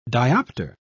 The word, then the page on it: dioptre: British English